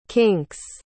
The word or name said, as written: kinks